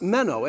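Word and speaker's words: meno (μένω): This word abide is pronounced meno, and it means to remain somewhere or to continue to be.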